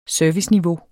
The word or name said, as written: serviceniveau